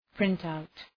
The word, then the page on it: printout: ‘prınt,aʋt